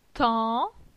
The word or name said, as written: Tan